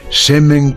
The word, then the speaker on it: Semen: en una sólida empresa que se llama Semen Cardona. Semen, insisto, no crean que me he pronunciado mal.